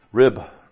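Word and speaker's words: rib (ריב): Okay, so the Lord speaks to the people of Israel because he has a controversy, or in Hebrew, rib. It's actually how it's pronounced.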